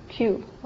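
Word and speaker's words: Kaeu: But it's K-A-E-U, K-A-E-U. And Q? Q, okay.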